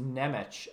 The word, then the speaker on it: Nemec: I was so confused when you said Nemesh. I was like, who are you talking about? Oh, I know. Okay, The Czech Republican turned Slovakian guy on the team. – Yeah. I think it's pronounced Nemec.